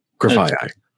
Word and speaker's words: Grafaiai: Grafaiai, because it's kind of like graffiti, because it's a graffiti thing, so yeah, it's got to be Grafaiai.